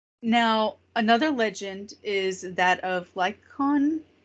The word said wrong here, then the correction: Lycaon: The Lycan or Lycon or... – Well, it's the person, Lycaon.